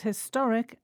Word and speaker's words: historic: We pronounce historic and historical with the stress on the same syllable in both words, the to.